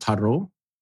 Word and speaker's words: Tarot: So he was in a band before Nightwish, an 80s kind of hair metal band called Tarot. Yeah. I think you Americans say. – Oh, tarot, like tarot cards.